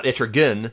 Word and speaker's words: Etrigan: And it's how I know, like earlier, I think I pronounced it Etrigan because I've heard that pronunciation, but all my life I called him Etrigan, not Etrigan, because, or else the rhyming doesn't work.